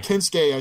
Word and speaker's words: Kensuke: Kensuke, I should say. They said his name wrong, and now I'm saying it wrong.